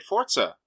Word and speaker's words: Forza: Am I saying it wrong, or are you saying it wrong? Because it sounds like you're saying it with a T. – It's how it's supposed to be pronounced, Forza.